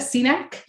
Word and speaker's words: Cenac: Cenac.